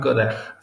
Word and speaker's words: Godard: Jean-Luc Godard, sí. – No sé, era como con una J al final, güey. – De la J, sí, o Godard, digamos.